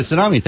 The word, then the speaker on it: tsunami: Tsunami. – Yes, the tsunami. Thank you. – And, you pronounce the T in that? – No, I don't. But.